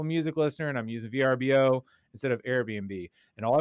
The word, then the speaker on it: Vrbo: I think it's like 50-50 the way people pronounce it. I still say VRBO. – They had like an ad. So they had a whole ad that was like, it's Vrbo.